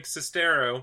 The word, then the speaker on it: Sestero: Then Greg Sinistero... Greg Sestero, if I can say his name right,